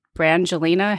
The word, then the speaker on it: Brangelina: I remember when I named Ami Lee, she was maybe 6 months old, and it was like in the Brad, how do you say that? Brad Brangelina heyday. How did they say that? – Brangelina. – Brangelina or something like that.